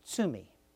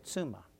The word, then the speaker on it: tsumi: The Japanese word for sin is tsumi, but I mispronounced it, and I said tsuma. And the word tsuma means wife.